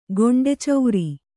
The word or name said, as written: ಗೊಂಡೆಚೌರಿ